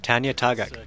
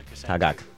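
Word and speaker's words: Tanya Tagaq: Tanya Tagak. – Tagak, right.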